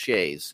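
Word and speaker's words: sidhe: You mentioned Shay, which I, S-I-D-H-E, I think they're pronounced Shays in Gaelic, which are, you called them fairy mounds.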